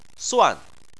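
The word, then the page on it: suan: tone 4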